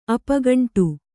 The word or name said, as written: ಅಪೋಗಂಡ